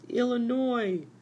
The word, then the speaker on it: Illinois: I was the one who chopped down the other tree to build my log cabin in Illinois. – Mr. Lincoln, you pronounce it Illinois.